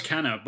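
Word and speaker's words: Kena: It's K-E-N-A. So is it Kenna? – Yeah, it was Kenna, but like, I watched a developer interview not too long ago on that, and he pronounced it Kenya or something.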